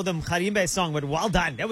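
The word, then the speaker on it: Mgarimbe: I can't pronounce just say Mkharimbe. Just like that. Mkharimbe.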